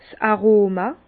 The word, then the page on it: 's Aroma: Bas-Rhin; Herrlisheim